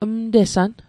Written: mtesan

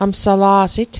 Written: amsala'sit

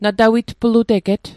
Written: natawitpluteget

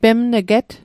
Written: pemn'get

